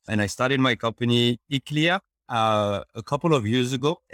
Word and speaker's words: Iklia: I hope I pronounced that correctly. – Yes, that's perfect, Adrian. Thank you so much. The name of the company is Iklia.